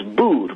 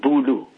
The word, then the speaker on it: boer: Now, the word for farmer in Dutch is boer, B-O-E-R, boer. But that soft R at the end, they could not pronounce, and you would struggle with it as well. So they, instead of saying boer, they said boulou. They put a lu at the end, because that's more in tune with their language.